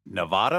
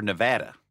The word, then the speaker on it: Nevada: Nevada or Nevada? – I've always said Nevada, but it actually is Nevada. That's yeah. – the way they pronounce it in Nevada.